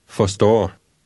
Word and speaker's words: Forstår: Forstår.